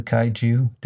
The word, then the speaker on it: kaiju: kaiju. Did I say that right? – Okay, you did.